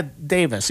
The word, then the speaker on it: Davies: He goes by Davis. Even though it's spelled Davies, they're the Davis brothers, Ray and Dave.